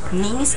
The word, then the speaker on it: Knees: Knees and elbows. Knees, K 不发音，K N and elbow, E L B Pavement And you try to avoid the pavement.